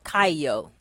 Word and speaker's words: kaio: And I, you know, I looked up the Hebrew meaning for light, which, and the word is kaio. It's spelled K-A-I-O.